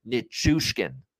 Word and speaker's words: Nichushkin: You look at the spelling of the last name, it's pronounced exactly as it's spelled. Nichushkin.